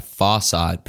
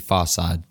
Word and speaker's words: Pharcyde: The next track is Other Fish by Farside, pronounced, spelt P-H-A-R-C-Y-D-E, Farside.